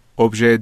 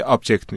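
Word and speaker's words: objet: ابژه داخلی موضوع عشق داخلی این تلفظ فرانسویش ابژه است به مثلا آمریکایی و انگلیسی آبجکت میشه بله.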